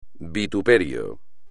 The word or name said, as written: vituperio